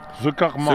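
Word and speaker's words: C'est Karma: Du kannst Französisch, Papa, wie spricht man das aus? C. Karma? Chest oder Cest? – C. Ce Karma. Ce Karma.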